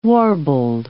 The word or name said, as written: warbled